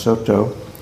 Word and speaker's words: Soto: He was a Japanese monk who went to China and brought back the Chinese Zaodong tradition, Japanese pronounced Soto.